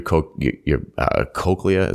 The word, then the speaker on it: cochlea: and that your cochlea, is that how you say it? – Yeah.